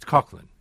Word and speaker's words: Coughlin: It's Coughlin.